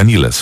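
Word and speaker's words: Nilles: Annika Niles w sumie, bo tak powinno wymawiać się jej nazwisko, to osoba, która urodziła się w 1983 roku, zatem 17 lat po powstaniu zespołu Rush w Aschaffenburgu.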